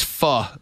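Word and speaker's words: phở: That's pho, by the way. – I've heard it pronounced faux.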